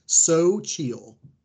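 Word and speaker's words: Xóchitl: Her first name is pronounced Sochil. – Sochil. Sochil. I actually don't know what it's on the first syllable.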